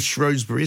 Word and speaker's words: Shrewsbury: Daniel Kalczynski there telling us that you can pronounce it Shrewsbury if you wish, or Shrewsbury. It's one of those great British things, isn't it, that people pronounce things in different ways.